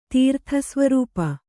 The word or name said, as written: ತೀರ್ಥಸ್ವರೂಪ